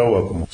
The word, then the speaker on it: Аввакум: Так произносится Аввакум? – Да. – Я почему-то думал Аввакум. – Аввакум – это по литературному на русском, да, Аввакум.